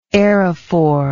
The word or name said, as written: aerophore